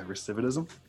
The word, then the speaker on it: recidivism: Then you get the recidivism. Am I saying that right? – Yeah. – Yeah, recidivism.